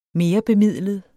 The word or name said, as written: merebemidlet